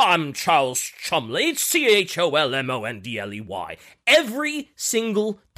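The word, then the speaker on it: Cholmondeley: And get a load of how this guy's name is spelled. So his name is Charles Chumley. How would you expect his name to be spelled? – Chumley. – Oh, I would expect that too, but no. Chomondeley.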